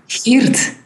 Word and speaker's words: Geert: Geert. – Geert. – The G and the R in the Dutch or in Flemish is rather hard for English speakers.